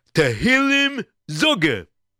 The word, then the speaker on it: Tehillim Zoger: No. I got the pronunciation right because he told me. Tehillim Zoger.